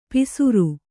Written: ಪಿಸುರು

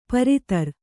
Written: ಪರಿದರ

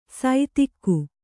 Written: ಸೈತಿಕ್ಕು

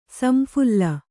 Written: ಸಂಫಲ

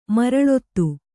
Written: ಮರಳೊತ್ತು

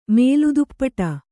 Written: ಮೂಲೋತ್ಪಾಟ

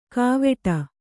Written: ಕಾವೆಟ

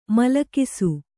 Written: ಮೂಲಗಿಸು

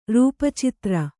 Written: ರೂಪಚಿತ್ರ

